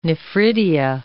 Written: nephridia

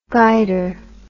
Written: guider

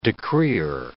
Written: decreer

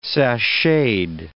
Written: sacheted

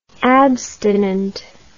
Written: abstinently

